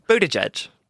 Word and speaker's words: Buttigieg: Buttigieg. – Buttigieg.